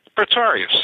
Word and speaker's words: Pretorius: The main character, I want to say his name correctly, is that Pretorius? – Pretorius.